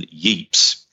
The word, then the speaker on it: Ypres: It followed a line that ran past the cities of Ypres, and I'm just gonna say I may make various pronunciations of Ypres, or Ypres, or even Yeeps, because I grew up near a town called Rye in Sussex. I've referred to it before, and there's a castle in Rye called Yeeps Castle or Yeeps Tower. – Okay. – And that's how I always pronounced it, and that's how my gran. I mean, it may just be my grandmother. That's how she pronounced it.